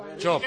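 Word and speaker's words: Čop: Chop, el Chop, del Málaga. Chop. Chop. El del Málaga. – Ah, sí, sí, sí, se escribe Cop.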